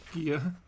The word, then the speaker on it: Gaia: But in this story, they like say it's, you know, how do I pronounce the Titan's name? Gaia? Gia? – Gia.